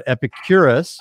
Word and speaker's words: Epicurus: But I've been saying Epicurus for years, even teaching it Epicurus. But Epicurus, we'll go with.